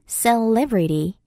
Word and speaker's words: celebrity: C-E-L-E-B-R-I-T-Y. celebrity. celebrity. 이음절의 강세가 있는 단어예요.